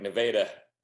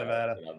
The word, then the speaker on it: Nevada: There's so many, so many Missouri towns are like Nevada, right? – Not Nevada.